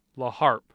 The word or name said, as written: LaHarpe